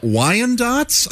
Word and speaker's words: Wyandotte: And two Wyandottes? I don't know how to pronounce this. – That's Wyandotte is how it's pronounced.